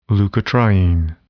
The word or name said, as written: leukotriene